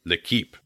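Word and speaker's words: L'Équipe: Or Tony Parker, who has said that he, to the French sports daily L'Equipe, did I say that right, French speaker? – L'Equipe, thank you.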